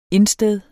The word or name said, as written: insted